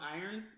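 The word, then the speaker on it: Irn: This is pronounced iron, even though there's a no O. Iron Brew, B-R-U.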